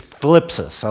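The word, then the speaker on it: thlipsis (θλῖψις): In Greek, it's thlipsis. I like that word. I don't know why. I think it's because it has a theta, lambda, has a THL sound at the beginning.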